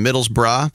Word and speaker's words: Middlesbrough: It's funny, they're Middlesbrough, That's how you say it, Middlesbrough, but the nickname is Boro.